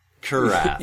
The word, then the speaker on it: Kurath: I thought that I might take this moment to quote Hans Kurath, or Kurat, or I have no idea how he pronounced his name. – Kurath. – Yeah, that sounds good. – K-U-R-A-T-H, yes, exactly.